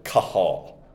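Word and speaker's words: kahal (קהל): There's a Hebrew word, kahal. In English letters, we'd spell it Q-A-H-A-L, which meant assembly.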